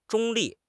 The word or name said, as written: 中立